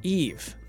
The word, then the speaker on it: Yves: Eve is pronounced Eve. Y-V-E-S, Eve. – Oh, there's no... – No S. So let me... – Hopefully we'll never forget that. – Let me just see if we got this straight. Eve Guillemot.